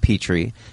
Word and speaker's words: Peteri: But just looking at it, it is spelled P-E-T-E-R-I. It's pronounced Petrie. And that isn't the natural first pronunciation when you read it, although now it will be for me.